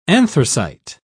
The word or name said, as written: anthracite